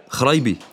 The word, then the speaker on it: Chraibi: My name is Aladdin Kharaibi. – Could you spell that, please? – Sure. I have to do that all the time. C-H-R-A-I-B-I. Kharaibi.